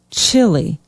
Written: chili

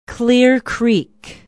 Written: Clear Creek

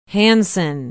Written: hansen

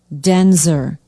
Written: denzer

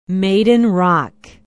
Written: maiden rock

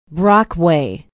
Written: brockway

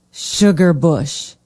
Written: sugar bush

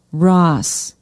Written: ross

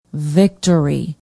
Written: victory

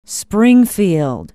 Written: springfield

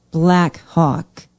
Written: black hawk

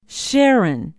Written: sharon

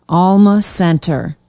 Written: alma center

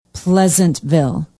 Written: pleasantville

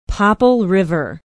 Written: popple river

